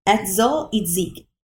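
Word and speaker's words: Etzel Itzik: So it's E-T-Z-E-L, Edsel. And then it's Itzik, I-T-Z-I-K. Edsel Itzik.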